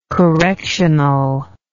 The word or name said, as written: correctional